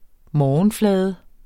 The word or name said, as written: morgenflade